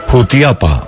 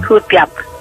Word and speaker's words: Jutiapa: De Jutiapa. Hoy sí lo dije bien. – Jutiapa.